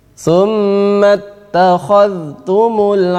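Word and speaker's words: summattakhadhtum (ثم اتخذتم): Summattakhadhtumul 'ijla. Ada dzal, ya, dzal bertemu dengan ta. Dzal wajib dibaca izhar. Jadi bacanya summattakhadhtum takhadhtum takhadhtum summattakhadhtum. Banyak orang rata-rata mereka mengidghamkan ini salah.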